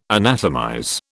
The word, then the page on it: anatomize: British English